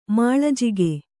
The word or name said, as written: ಮೇಲುಜಗ